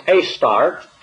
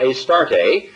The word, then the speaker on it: Astarte: or Ashtaroth with a silent H, and pronounced Astart or Astarte by the ancient Sumerians, Babylonians, Zidonians, and then eventually by the Egyptians and the Greeks.